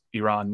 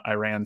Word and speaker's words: Iran: And the Iranian press member goes, first of all, you're pronouncing your country wrong. It's pronounced Iran, not Iran.